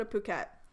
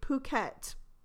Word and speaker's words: Phuket: Okay, let's go to Phuket. Contrary to popular belief, that is how you say it. You say Phuket, not other profane words.